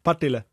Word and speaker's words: Partille: Wird es Partille oder? – Ja, Partille.